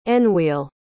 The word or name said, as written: enwheel